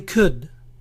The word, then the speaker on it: could: Yo podría, I could. Recuerden dejar de pronunciar la L. I could.